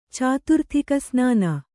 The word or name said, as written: ಚಾತುರ್ಥಿಕಸ್ನಾನ